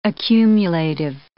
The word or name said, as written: accumulative